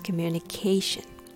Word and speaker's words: communication: How to pronounce communication? Let's break it into parts. Communication. Syllable number one, K. This is a relaxed, unstressed syllable. Don't say calm, it's K, K.